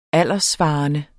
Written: alderssvarende

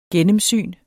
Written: gennemsyn

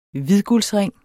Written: hvidguldsring

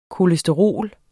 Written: kolesterol

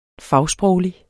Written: fagsproglig

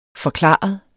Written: forklaret

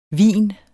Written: hvin

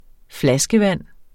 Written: flaskevand

